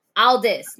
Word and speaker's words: Aldis: atlas again atlas Aldis. Aldis. Nick Aldis. That, I knew I was saying it wrong yeah, for a second.